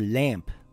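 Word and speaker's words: Lam (لام): Lam is always pronounced light, except in the name of Allah, which will be explained later in detail. Lam makes a similar sound in English to the letter L in the word lion or the word lamp.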